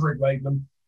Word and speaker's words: Rick Wakeman: I love Wick Rakeman. Wick Rakeman, I've gone for there. – Zedlist, and you're mispronouncing his name. He's gonna hate me. – No, I love Rick Wakeman.